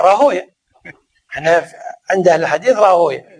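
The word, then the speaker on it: راهويه: راهوي، لا راهوي هذا عند أهل اللغة، راهوي سيبوي نفطوي، أما عند أهل الحديث سماعا راهوية سيبوية نفطوية.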